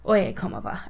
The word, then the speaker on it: Oye Como Va: They were doing Ole oh, Como Va. I'm not in that. Oye Como Va. I always say it wrong.